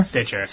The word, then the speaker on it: Stitcher: And, of course, we become a podcast, usually sometime on Sunday, on both iTunes and Sketcher. I always say it wrong. Stitcher? – Stitcher.